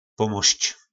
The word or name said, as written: pomosc